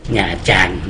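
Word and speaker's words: Nha Trang: หญ้าจัน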